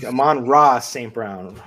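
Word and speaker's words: Amon-Ra St. Brown: I think you might've forgotten a syllable in there somewhere. – Yeah, there. That's what I think. Amon Ross St. Brown.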